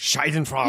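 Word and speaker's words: Schadenfreude: Scheidenfrage.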